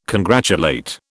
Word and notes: congratulate: British English

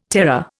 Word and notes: terror: Indian

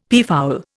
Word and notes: peafowl: Indian

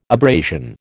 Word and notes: abrasion: American English

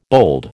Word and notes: bold: American English

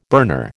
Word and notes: burner: American English

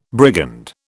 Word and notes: brigand: British English

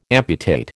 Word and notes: amputate: American English

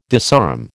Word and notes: disarm: American English

